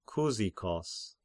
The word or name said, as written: Kyzikos